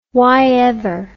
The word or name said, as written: whyever